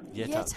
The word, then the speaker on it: Jette: And I've been called lots of different things, yeah, because it's spelt like J-E-T-T-E.